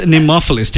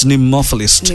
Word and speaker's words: nemophilist: It's spelled nemophilist. So it's like Nemo. Like Nemo. Yeah, it's nemophilist.